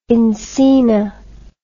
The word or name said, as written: encina